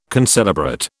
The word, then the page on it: concelebrate: British English